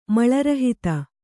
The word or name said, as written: ಮಲರಹಿತ